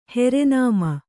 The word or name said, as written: ಹೆರೆನಾಮ